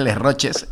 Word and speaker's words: Les Roches: Que claro, cuando se lee en castellano es Les Roches, ¿eh? Sí. Y además para buscarlo en internet es mejor que se pronuncie con todas las letras, como digo yo.